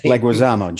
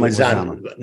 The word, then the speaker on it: Leguizamo: Leguizamo. John Leguizamo.